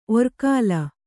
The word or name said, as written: ಒರ್ಕಲು